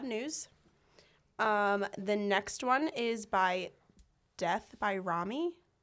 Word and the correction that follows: Romy: Romy? Death by Romy? – Never heard. – R-O-M-Y.